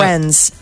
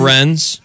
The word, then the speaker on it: Wrens: The Wrens. Wren, like W-R-E-N, like the bird. – Okay. – The Wrens? – Yes.